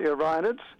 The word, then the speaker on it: Orionids: Orionids meteor shower? – That's it, the Orionids.